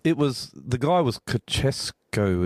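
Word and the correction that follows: Ceaușescu: is that right? Is that how you say his name, the leader back then? – Ceausescu. – Ceausescu, yeah.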